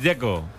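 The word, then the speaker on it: Džeko: De Z, ¿cómo se pronuncia? – Dzeko. Bruno Dzeko. Dice que es Dzeko. – Como tirando a J, Dzeko o algo así.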